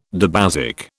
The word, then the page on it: dibasic: British English